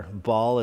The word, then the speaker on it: Baal: I was worshiping the god of Baal, or Baal is how some people pronounce it.